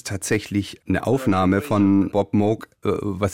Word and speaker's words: Moog: It's originally a Dutch name, and in, in Holland you would pronounce it Moch. Now that's a little bit too much to expect people to do here. Uh, in Germany, uh, it's a fairly common name, and there it's pronounced Moog.